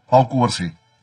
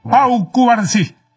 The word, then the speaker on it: Pau Cubarsí: Pau Cubarsí. – Yes. Pau Cubarsí.